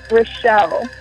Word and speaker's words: Rachele: Rachele.